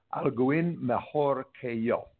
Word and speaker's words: Alguien mejor que yo: But there's a couple of combinations of letters that I never quite get right, and one of them is Alguien mejor que yo. Did I say that right? – That is perfect. You got it, yes.